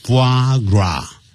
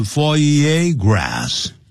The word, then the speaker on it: foie gras: 好，这里都有几个法语啊，法文。好，一开头讲 foie gras 啊，foie gras，那标念成 foie gras